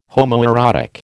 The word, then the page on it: homoerotic: American English